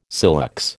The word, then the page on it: silex: American English